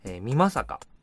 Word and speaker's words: 美作: この、特急は地図急行の車両でして HOT7000、この佐与、光月、美作土井、この辺りからだんだんですね、のんびりとした景色になってきて、県も岡山県に入っていきます。美作、美しい、作ると書いて美作と読みますけども、その津山っていうね、津山の、勢力範囲の中にだんだん入っていきます。